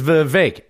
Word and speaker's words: Vivek: My name's Vivek. It rhymes with cake.